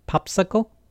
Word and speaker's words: PopSQL: Next piece of content, building the best PostgreSQL GUI, announcing our acquisition of PopSQL, or I think it's pronounced Popsicle,